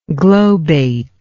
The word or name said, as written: globate